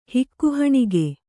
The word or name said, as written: ಹಿಕ್ಕುಹಣಿಗೆ